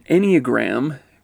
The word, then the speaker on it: Enneagram: An Enneagram, because everybody's like, what did you just say when they first hear it, is spelled E-N-N-E-A-G-R-A-M. Enneagram, okay?